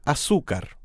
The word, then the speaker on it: azúcar: This can be a difficult word to pronounce because the stress falls in the middle. Try it once more. – Azúcar.